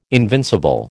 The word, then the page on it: invincible: American English